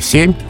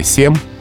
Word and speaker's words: семь: Там, где москвичи говорили семь, восемь кровь, петербуржцы говорили семь, восемь кровь.